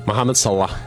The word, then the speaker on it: Mohamed Salah: Mohamed Salah.